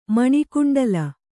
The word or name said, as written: ಮಣಿಕುಂಡಲ